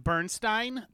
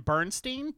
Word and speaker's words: Bernstein: But according to the movie, it's pronounced Bernstein. But the entire time while I was making notes, I kept calling him Bernstein because of Woodward and Bernstein.